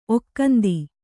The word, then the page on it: ಒಕ್ಕಂದಿ: Kannada